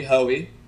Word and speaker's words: Hoey: So Joey Hoey, that is my last name. It rhymes 100%. You'll never forget my name.